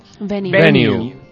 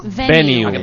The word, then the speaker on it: venue: Venue. – ¿Cómo? – Venue. – ¿Cómo? ¿A venue? – Se escribe V-E-N-U-E.